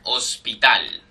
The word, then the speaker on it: hospital: Hermosa, Hospital. – hospital. Notice that in all of these words in box 38, the H, they all begin with H, but the H in Spanish is silent.